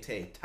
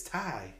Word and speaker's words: Tay: Why do I keep saying Tay? Ty Conti, excuse me. I don't want Negative One to yell at me because he said that's how you say it. He's like, it's Ty.